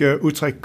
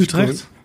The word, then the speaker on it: Utrecht: I think Utrecht could be a good example for all the Dutch listeners. But I think the green transition is Utrecht.